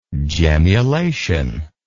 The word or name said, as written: gemmulation